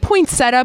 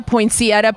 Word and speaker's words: poinsettia: Is it poinsettia, poinsettia, poinsettia? – Yes. – All of them? – Yes. You know, the interesting thing about plants is there is no official pronunciation.